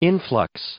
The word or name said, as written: influx